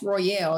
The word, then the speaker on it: Royal: And, you know, hearing the name, it was like, oh, my name is Royal. Then a lot of people will pronounce, oh, that's Royale. And I was like, no, my name is Royal.